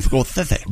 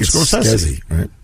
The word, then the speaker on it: Scorsese: Martin Scorsese. – Born in 1942. Happy birthday, Marty. – How do you pronounce it? – Martin Scorsese. – Scorsese? – Yeah.